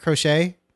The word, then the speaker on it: crochet: The difference between croquet and... I mean, crochet. Now I'm confused. How do we say it? Crochet? Right? And knitting. – Crochet, yeah.